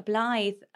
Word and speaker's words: Blythe: It must be just Blythe. Blythe. – Oh, God. Well, that's terrible. – Blythe. I mean, it literally is phonetic, isn't it? – Yeah. – B-L-Y-T-H-E.